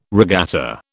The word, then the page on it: regatta: British English